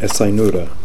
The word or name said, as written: Esai Noora